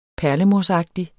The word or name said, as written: perlemorsagtig